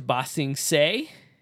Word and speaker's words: Ba Sing Se: They then go on a journey to go to Ba Sing Se.